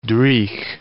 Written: dreich

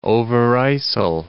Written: Overijssel

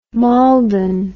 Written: Maldon

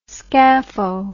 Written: scapho

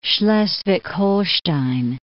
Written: Schleswig-Holstein

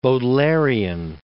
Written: Baudelairian

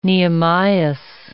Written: Nehemias

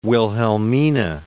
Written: Wilhelmina

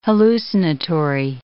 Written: hallucinatory

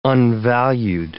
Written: unvalued

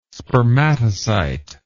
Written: spermatocyte

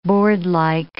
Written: boardlike

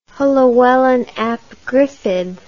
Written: Llywelyn ap Gruffudd

